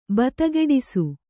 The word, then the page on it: ಬತಗೆಡಿಸು: Kannada